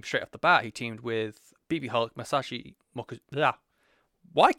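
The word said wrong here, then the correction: Masaaki Mochizuki: Masaki Mochizuki, Masato Yoshino.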